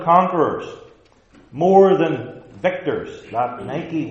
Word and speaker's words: Nike: And the Greek word for victory, used twice in this verse, is Nike. I pronounce it the contemporary way, not the Greek way. Nike, victory.